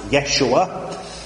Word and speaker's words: Yeshua: How it was pronounced back then? Well, generally the feeling is it was probably pronounced Yeshua.